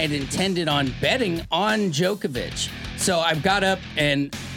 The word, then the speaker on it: Djokovic: Well, the Wimbledon situation over the weekend was surprising to say the least with Novak Djokovic, with the silent D, if you ever wonder.